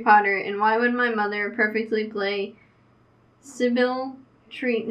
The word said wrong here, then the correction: Trelawney: Sybil Trelawney.